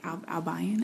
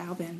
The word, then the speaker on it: Albion: I never thought Albin was hard to pronounce until I left and people are like, Albion. – I know. I don't know. – Yeah. I'm like, it's just Albin. Don't overthink it.